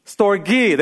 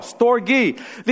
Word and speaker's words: storge: How do you say that? Storge, there you go. Storge.